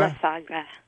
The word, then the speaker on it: La Sagra: What is the word again? La Sagra? – La Sagra. Yes. – How do you spell that? – L-A. Yeah. S-A-G-R-A. – La Sagra, just like it sounds.